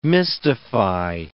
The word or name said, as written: mystify